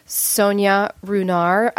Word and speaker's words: Sonia Runar: This morning we have Sonia Runar.